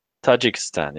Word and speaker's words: Tajikistan: Tajikistan? – Tajikistan, yes. – Tajikistan, Pamir region, should be returned to China. These are about those poor minorities getting abused by the CCP, man. – Yeah, Tajikistan is actually a sovereign nation on the border with China.